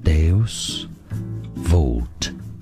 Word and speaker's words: Deus Vult: In English, it's pronounced as Deus Vult, a Christian motto relating to divine providence, meaning God will.